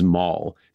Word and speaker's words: Mal: The king of the Derevlians at this time is Maul, spelled M-A-L. So I might kind of switch between pronouncing it like Darth Maul and like Mal from Firefly as I go through the story. It's like Olya, how there's just different ways of saying the name.